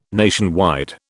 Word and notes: nationwide: British English

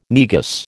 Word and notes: Negus: American English

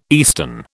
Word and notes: eastern: British English

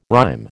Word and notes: rhyme: American English

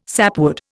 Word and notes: sapwood: Indian

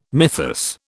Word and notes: mythus: British English

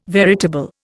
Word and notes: veritable: Indian

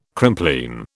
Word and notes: Crimplene: British English

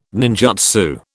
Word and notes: ninjutsu: British English

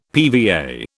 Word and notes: PVA: British English

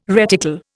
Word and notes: reticle: Indian

